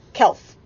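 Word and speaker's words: Kalf: It's K-A-L-F. Kelf. It looked like it should be calf, like, you know, but it's Kelf.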